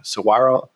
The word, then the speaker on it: Saguaro: So last time I was in Arizona, I took a run through like part of the Saguaro Desert. Am I pronouncing that? Or is there a G in there? Saguaro? – No, you did it. Saguaro. You did it.